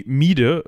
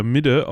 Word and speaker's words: Mide: Äh, oder auch Mi Mide oder Midde ausgesprochen, was eben einfach so viel heißt wie die Mitte.